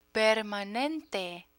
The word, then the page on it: permanente: Spanish